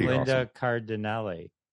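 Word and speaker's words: Linda Cardellini: That was Linda Cardinale.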